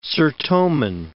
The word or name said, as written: Sertoman